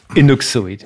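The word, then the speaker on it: Inuksuit: four syllables, Inuk Seward. Mm-hmm. Because some people say, you know, Inuk Shud would be like the singular form, and Inuk Seward would be more of those stone artifacts.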